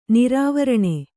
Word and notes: ನಿರಾವರಣ: nirāvaraṇa

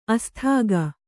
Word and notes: ಆಸ್ತಿಕ: āstika

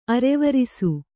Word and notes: ಅರವರಿಸು: kannada

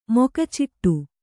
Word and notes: ಮೊಕಚಿಟ್ಟು: moka ciṭṭu

